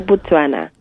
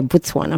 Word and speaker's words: Botswana: Am I saying it correctly? – Well, they say Botswana. – Okay, Botswana.